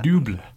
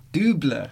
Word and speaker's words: double: Anyone want to give me a pronunciation on that? – Double. – Double. That's much better.